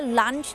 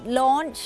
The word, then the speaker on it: launch: lunch นะคะแต่ว่าไม่ใช่ lunch นั้นมันก็คือมื้อกลางวันใช่ไหมคะอาหารกลางวันจริงๆแล้วต้องออกเสียงว่า launch นะคะนั่นก็จะเป็นสำเนียงอังกฤษ launch